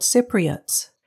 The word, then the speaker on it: Cypriots: The inhabitants are called Cypriots, with the first syllable containing the P sound.